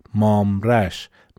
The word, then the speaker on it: مامرش: تلفظش اینطوره مامرش مثل ماموستا مام استاد میگن ماموستا مام به علاوه استاده به علمای بزرگ دین میگن بله مرسی از تذکرتون مامرش مام یک پیشفند برای بزرگ است